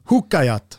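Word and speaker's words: Huuhkajat: De kallas för Hukajat i eh, folkmun. Hukkajat.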